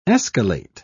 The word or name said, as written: escalate